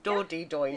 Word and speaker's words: Dordogne: So, as you've very kindly mentioned, much of the novel is set in France in a place called the Dordogne, which I can't pronounce and I don't know where it is. – That's it. – Dordogne.